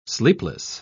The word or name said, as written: sleepless